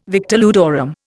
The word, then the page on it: victor ludorum: Indian